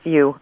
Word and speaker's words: Yu: Is it Yu or Yu? – It's Yu. I don't speak Cantonese or German. It's Yu.